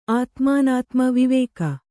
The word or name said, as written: ಆತ್ಮಾನಾತ್ಮ ವಿವೇಕ